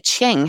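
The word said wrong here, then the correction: Cheong: How did you pronounce his last name? – Look, I actually watched 2 YouTube clips earlier today. – Right. – And they said Chong, but Chong. – You... – Oh, that makes more sense, because there is definitely an ong sound. It's C-H-E-O-N-G.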